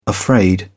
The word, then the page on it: afraid: British English